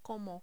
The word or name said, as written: como